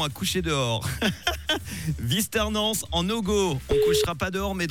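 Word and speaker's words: Vuisternens-en-Ogoz: Eh ben super, je l'ai bien prononcé, Visternance en Ogo.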